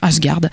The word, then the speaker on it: Asgard: Le 26 janvier, la semaine suivante, ça se passe à Champy, dans le magasin Asgard, je ne sais pas comment on prononce. – Asgard.